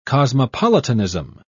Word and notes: cosmopolitanism: British English

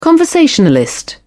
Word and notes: conversationalist: British English

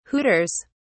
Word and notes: hooters: British English

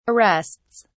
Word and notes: arrests: British English

